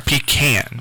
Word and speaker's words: pecan: Ever since Thanksgiving, I've been eating and is it pecan or pecan? – I pronounce it pecan.